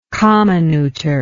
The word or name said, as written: comminutor